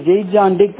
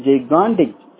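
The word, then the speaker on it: gigantic: இது வந்து ஜெய்காண்டிக் அப்படின்ற ஒரு ப்ரொனன்சியேஷன்ல படிச்சிருக்காங்க. ஆனா அது ஜெய்காண்டிக் கிடையாது, ஜெய்காண்டிக் சரிங்களா, ஜெய்காண்டிக் ஜெய்காண்டிக் அப்படின்ற மீனிங்.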